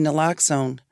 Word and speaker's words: naloxone: Maybe they should not be allowed to release someone from rehab without some naloxone.